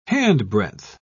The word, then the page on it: handbreadth: British English